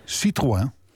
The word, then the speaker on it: Citroën: Wil je even het merk Citroën uitspreken? – Citroën? – Oh ja, we kregen een mailtje dat je het voortdurend verkeerd zegt. – Oh. – Maar volgens mij doe je het hartstikke goed. Citroën, ja, die is een mevrouw, François van Haaf, die zegt dat jij altijd Citroën.